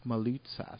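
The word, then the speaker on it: Moulitsas: By the way, let's speaking of liberal bias, I think it's worth going into the guy from Daily Kos, Marcos Maloutsas. Am I pronouncing his name right? – Absolutely.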